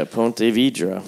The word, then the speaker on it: Ponte Vedra: Yeah, Ponte Vedra.